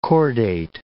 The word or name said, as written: chordate